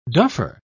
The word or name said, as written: duffer